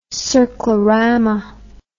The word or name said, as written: circlorama